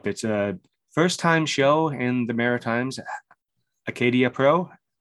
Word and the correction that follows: Acadie: Acadie Pro. Yeah, it's very French.